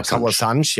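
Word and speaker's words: Kauã Santos: Kaur Santosch, ja, genau. So wird es portugiesisch richtig ausgesprochen.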